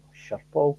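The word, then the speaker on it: Schiphol: You know, I travelled via Sherpol, is that how you pronounce it, in Amsterdam. – Yeah.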